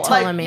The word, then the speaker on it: Ptolemy: So like if they were pronouncing it the correct Egyptian way, they would this P would be silent, correct? – Right. – So, so just like, be like Tila, like Ptolemy, like, yeah, yeah, Ptolemy's Cleopatra.